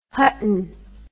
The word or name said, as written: Hutton